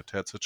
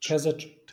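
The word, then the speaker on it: Terzić: Terzic. – Terzic. – Terzic.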